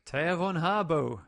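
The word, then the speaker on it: Thea von Harbou: What is that name? – Thea von Harbo. – There you go.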